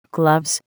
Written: gloves

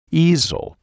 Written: easel

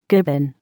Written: gibbon

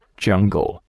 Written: jungle

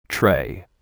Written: tray

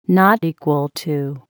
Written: not equal to

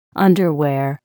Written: underwear

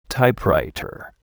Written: typewriter

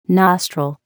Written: nostril

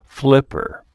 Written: flipper

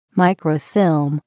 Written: microfilm

